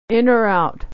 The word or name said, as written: or out